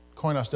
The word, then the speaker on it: koinos: Well, the word translated fellowship in the New Testament has its root in an adjective, which is pronounced koinos.